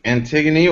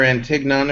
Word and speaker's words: Antigone: We're discussing Antigone, or Antigone, if you pronounce it incorrectly, like I originally did. – Antigone.